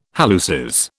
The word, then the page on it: halluces: British English